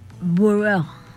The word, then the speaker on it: bureau: La palabra en francés es burro. Pero es B, a ver si lo pronuncié bien, que alguien me diga. Es B-U-R-E-A-U, ¿ok?